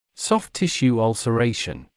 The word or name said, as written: soft tissue ulceration